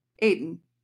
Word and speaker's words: Eitan: What I found is that it's pronounced Eitan. Aiden. Like Aiden, maybe? – All right.